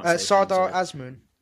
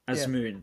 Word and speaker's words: Sardar Azmoun: Sardar Azmoun. – Azmoun, him.